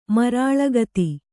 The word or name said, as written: ಮರಾಳಗತಿ